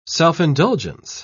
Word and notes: self-indulgence: British English

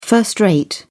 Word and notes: first rate: British English

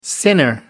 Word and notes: sinner: American English

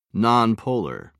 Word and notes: nonpolar: British English

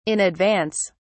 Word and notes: in advance: British English

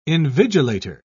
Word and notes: invigilator: British English

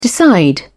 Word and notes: decide: British English

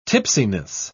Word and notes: tipsiness: British English

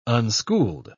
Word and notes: unschooled: British English

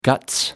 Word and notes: guts: British English

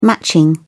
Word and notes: matching: British English

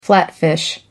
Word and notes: flatfish: American English

